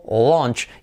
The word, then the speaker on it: launch: Y luego esta vocal tan curiosa, que por escrito es AU en este caso, pero es que una A en fonética es como una O con dos puntitos, ¿vale? Entonces se pronuncia así, launch. Como referencia, tenéis la palabra, el pasado del verbo ver, sí, saw, thought, y luego pondríais launch, como palabras patrón, ¿vale? Que llamamos saw, thought, launch.